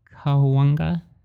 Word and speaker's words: Cahuenga: When I first moved here, I called it Cahuenga because I did not know how to pronounce it. You'd be like, is that Cahuenga? No, it's Cahuenga. So don't make the mistake I did. You should instead call it Cahuenga.